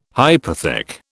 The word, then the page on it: hypothec: British English